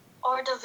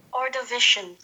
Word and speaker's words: Ordovician: Now, I mean, I'm not sure if uh, that's exactly how you pronounce it. So I Ordovician. division. – Or division. Wait, what was it? – Ordovician.